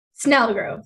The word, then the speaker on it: Snellgrove: Snellgrove with an N.